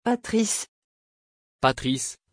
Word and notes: patrice: French

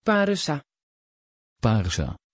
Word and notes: parisa: Dutch

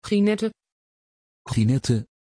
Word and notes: Ginette: Dutch

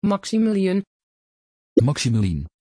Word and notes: Maximilien: Dutch

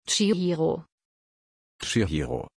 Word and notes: chihiro: German

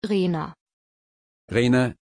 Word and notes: rayner: German

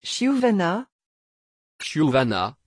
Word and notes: chiuvana: French

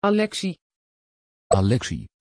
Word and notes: Aleksi: Dutch

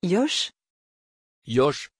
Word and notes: Yosh: French

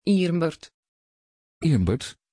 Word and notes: irmbert: Dutch

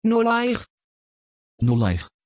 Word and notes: Nollaig: Dutch

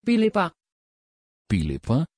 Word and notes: philippa: Dutch

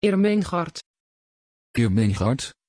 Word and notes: irmengard: Dutch

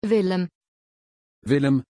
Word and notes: villem: German

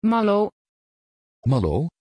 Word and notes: Maylo: Dutch

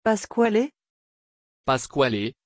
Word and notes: pasquale: French

